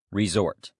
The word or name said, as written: resort